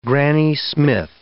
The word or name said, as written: Granny Smith